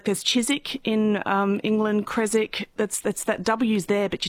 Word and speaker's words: Creswick: because it should be Creswick, not Creswick. That W should not be pronounced.